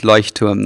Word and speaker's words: Leuchtturm: It's Leuchtturm 1917.